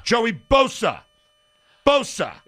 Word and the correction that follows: Bosa: It's Boza. Like a Z, it's Boza.